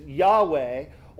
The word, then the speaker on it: Yahweh: Or we would sometimes pronounce this as Yahweh.